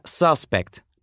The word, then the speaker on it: suspect: Пример. Существительное suspect — подозреваемый. В нем, как вы слышите, ударение ставится на первом слоге.